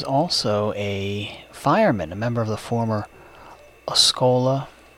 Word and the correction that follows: Osceola: am I pronouncing that right? Osceola Hose Company, and at one time the chief engineer of the Elizabeth Fire Department.